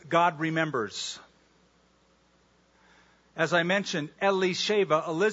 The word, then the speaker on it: Elisheba: I prefer the Hebrew pronunciation, Elisheba. Anybody here named Elizabeth? Okay, your name in Hebrew, the original pronunciation of your name, not the anglicized Elizabeth, is Elisheba.